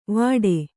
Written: ವಾಟೆ

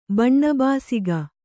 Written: ಬಣ್ಣಬಾಸಿಗ

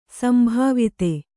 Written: ಸಂಭಾವಿತ

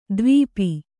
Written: ದ್ವೀಪಿ

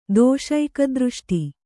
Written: ದೋಷೈಕದೃಷ್ಟಿ

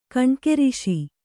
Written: ಕಣ್ಕೆರಿಶಿ

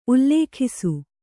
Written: ಉಲ್ಲೇಖಿಸು

